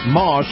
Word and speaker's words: Maus: Now, this portion of the program brought to you by our friends at Moss, that's spelled M-A-U-S, Acura. And if you love the feel of a high-end SUV, you're going to love Moss Acura.